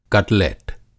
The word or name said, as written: कटलेट